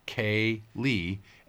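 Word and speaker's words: ceilidh: A Cayley, Anya had explained to Amy and me, is pronounced Cayley and is a gathering for Scottish music and often country dancing.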